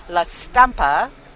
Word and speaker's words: La Stampa: As a follow-up to last week's show on the Amazonia Synod, in an interview with Italian newspaper La Stampa, okay, you Italian guys, did I pronounce it right? – Si, okay.